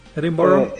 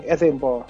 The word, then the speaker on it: Edinburgh: Y no lo pronuncié bien, no sé, ¿cómo se pronuncia Edinburgh? – Edinburgh. Lo pronunciaste casi bien, Edinburgh. – Ok. Entonces lo que ellos... – Nos falta el acento escocés, sí. Pero lo pronunciaste bastante bien.